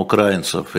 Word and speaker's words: украинцев: Огромное число э, украинцев или украинцев, как правильно? Украинцев. Украинцев, будем на «и» ставить ударение.